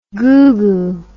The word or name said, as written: googoo